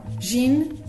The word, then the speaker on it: gin: termina em N. Então, isto significa que os portugueses devem dizer gin ou gin, quando muito, não é? Gin, como se tivesse quase um Ezinho no fim, mas que não está na grafia.